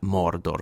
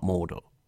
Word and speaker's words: Mordor: You have to say it like that, Mordor, because Gandalf does, and not Mordor.